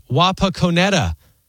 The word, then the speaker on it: Wapakoneta: Gerald, I'm a few weeks behind, but if nobody's mentioned it, Wapakoneta, Ohio is pronounced Wapakoneta. I think I probably still got it wrong.